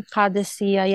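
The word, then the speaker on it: Qadisiyah: I've got the pronunciation right. – So my mother is from Qadisiya. Yeah, you got it absolutely right.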